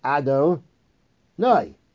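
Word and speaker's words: Ado, Noi (אדני): We pronounce this name as if it was spelled Aleph, Dalid, Nun, Yud, Ado, Noi, which means master.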